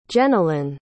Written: jenilynn

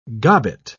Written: gobbet

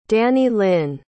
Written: danylynn

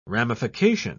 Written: ramification

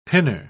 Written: pinner

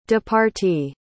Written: departee

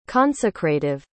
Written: consecrative

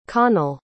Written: conall

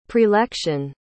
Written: prelection

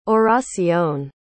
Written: oracion

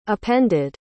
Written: appended

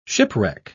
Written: shipwreck

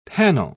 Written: panel

